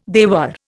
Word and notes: dewar: Indian

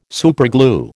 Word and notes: superglue: American English

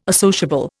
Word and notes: associable: Indian

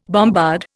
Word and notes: bombard: Indian

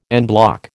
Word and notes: en bloc: American English

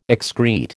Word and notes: excrete: American English

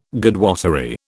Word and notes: Godwottery: British English

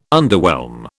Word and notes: underwhelm: British English

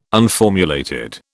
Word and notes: unformulated: British English

